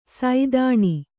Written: ಸಾದಣ

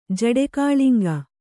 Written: ಜಡೆಕಾಳಿಂಗ

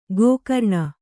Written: ಗೋಕರ್ಣ

